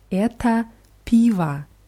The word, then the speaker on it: это пиво: Eto, eta piva. Es wird mit o geschrieben, es wird aber mit a ausgesprochen. Eta piva.